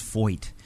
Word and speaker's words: Feucht: I think the coolest thing that happened is that I learned how to pronounce Foyt. It's F-E-U-C-H-T. So in German, it would be like Feucht, but he pronounces it Foyt.